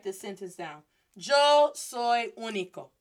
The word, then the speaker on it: único: So this is pronounced as único. Único. Notice it's still the U. It's not a short vowel or anything, but with that accent, I just stress it more, emphasize it more.